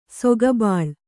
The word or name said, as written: ಸೊಗಬಾಳ್